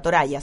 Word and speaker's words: Toraja: O sea, en Oceanía, se escribe, para los que les interese más y quieran meterse a internet, se llama Cultura Toraja, se escribe con J, Toraya se dice. Pero es T-O-R-A... J.